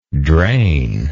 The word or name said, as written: drainable